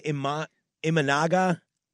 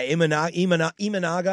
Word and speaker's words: Imanaga: How do I say Imanaga? – I'll give it to you. – Yeah, Imanaga, I believe is how you say it.